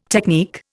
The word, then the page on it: technique: Indian